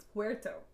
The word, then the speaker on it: Puerto: I was saying Puerto Vallarta, but it's Puerto. – Puerto. – You're supposed to roll your R.